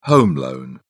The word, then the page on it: home loan: British English